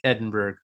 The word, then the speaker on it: Edinburgh: Edinburgh? That's how you say that, right? – Yeah, Edinburgh. – Edinburgh. I think I was thinking there was like some sort of syllable at the end. – Yeah. – Even though it's not spelled that way.